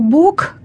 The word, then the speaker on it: Бог: Но на самом деле, по нормам орфоэпическим, то есть произносительным нормам русского литературного языка, в конце слова «Бог» нужно произносить не звук «к», а звук «х», «бог».